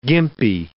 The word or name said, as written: gimpy